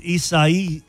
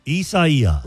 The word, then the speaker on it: Isaias: It's pronounced Isaias. – Isaias. – Isaias. With the syllable stressing the third syllable. The four-syllable word, Isaias.